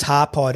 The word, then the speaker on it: Tapod: I think that when we came up with the name, we probably didn't think it through properly, because people call it Tapod, T-A-Pod, but we call it Tarpod, as in like a piece of tar baby.